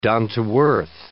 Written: down-to-earth

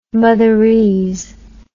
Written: motherese

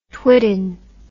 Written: twitten

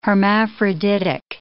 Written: hermaphroditic

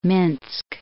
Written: Minsk

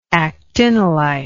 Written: actinolitic

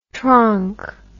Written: tronk

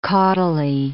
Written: caudally

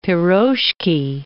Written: piroshki

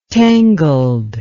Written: tangled

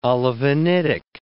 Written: olivinitic

